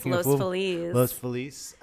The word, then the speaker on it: Los Feliz: We are in sunny Los Feliz. Am I saying that right? – Yes, Los Feliz.